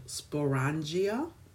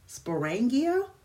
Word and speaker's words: sporangia: But when they were dispersing their spores through the wind, these tiny dust like spores come from structures that are called sporangia. I might be saying that wrong, but what's really wrong? Sporangia? Sporangia? S P O R A N G I A.